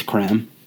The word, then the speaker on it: Creme: Cadbury's reply and said, it's creme. So, it's creme, not cream egg. My thoughts are, I'd have called it a cream egg because that's just what I would have thought it was called. Except whenever you take a step back and read how it's pronounced, it's creme. It's spelled C-R-E-M-E, which is definitely creme.